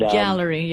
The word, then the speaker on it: Galleri: I'll just do this Galleri test. I hope I'm pronouncing that right. – Yep. And, Galleri, yeah. – Yeah, the Galleri. Galleri, yeah, yeah, for cancer, yeah.